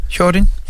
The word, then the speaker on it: Hyorin: og det kan godt være, at jeg udtaler navnet forkert, Hyorin? – Ja, Hyorin. – Ja.